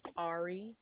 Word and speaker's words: Ari: Ari. – Can you spell that? – A-R-I.